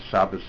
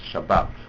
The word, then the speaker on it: Shabbat: So he said, that if you take this miris, Hashom Shabbos Abedin Mabas, it only rhymes if you pronounce Shabbos Shabbat. Then it rhymes with Bat. But if you say Shabbos and Bas, don't rhyme, so it's obvious that the Sephardi is right.